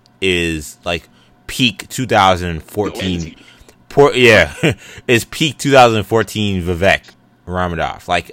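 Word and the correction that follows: Ranadivé: that, I mean, that's like Redeemish or whatever, I can't pronounce the last name. – Ronadive, there we go.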